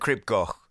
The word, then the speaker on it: Crib Goch: Cribgoch.